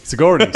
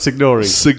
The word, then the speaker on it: Sigourney: Sigourney. Sigourney is one of the two actresses I've always pronounced wrong. Talking about names, I've always pronounced... Which one is it again? I call it Signori.